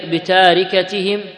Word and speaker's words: بتاركتهم: بتاركتهم ولست بتاركتهم. – أحسن الله. – هي ربما في بعض النسخ تكتب بالتاء بعدها الراء مباشرة لكنها تنطق بالألف. – نعم. قالت ولست بتاركتهم هكذا وهكذا إن إنما هم بني.